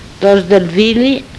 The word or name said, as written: Dos del vili